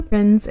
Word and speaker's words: Prinz: And if you're wondering who Prinz is, and that's Prinz, P-R-I-N-Z, not just mispronouncing the word Prince, that was Lorena's dog, who Eleanor bought a present for Christmas, which is absolutely adorable.